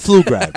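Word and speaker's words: Flugrad: It's not Ploof. – It's Flu Grad, bro. The P is silent, right? This is the second year I've been telling you this. Her name is Amanda Flu Grad. – Mark D'Amico can't do shit. – Don't pronounce the P, all right?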